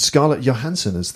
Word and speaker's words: Scarlett Johansson: They cast Scarlett Johansson is actually how you pronounce it.